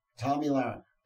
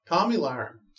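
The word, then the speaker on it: Tomi Lahren: Tommy Lahren. – Tommy Lahren.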